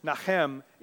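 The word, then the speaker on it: nacham: The Hebrew word, nachem, is that word for relenting or changing. And it's spelled N-A-H-A-M, which sounds like Nahum as well, which is another whole service that we could talk about.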